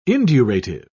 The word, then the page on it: indurative: British English